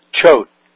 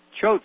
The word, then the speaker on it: Choate: Choate. – Choate School.